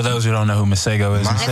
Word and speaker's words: Masego: Speaking of beats, I heard y'all was in here listening to FKJ and Mace God. – Oh, Masego? – Yeah. Is that how you pronounce it? Masego? – Yeah.